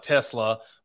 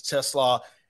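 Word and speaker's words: TeSlaa: It's how he spells it and how it pronounced it. Looks like Tesla, but it's Tesla.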